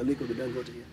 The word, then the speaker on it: Aliko Dangote: But you know and have a relationship with Aliko Dangote. Am I saying his name correctly? – Aliko Dangote, yeah.